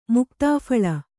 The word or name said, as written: ಮುಕ್ತಾಫಲ